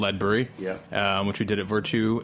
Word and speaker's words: Ledbury: How's that pronunciation? – Yeah, Ledbury was Ledbury, very good pronunciation. – Ledbury. – Yeah, Ledbury. – These guys, they're working on their... – not too heavy on the lead.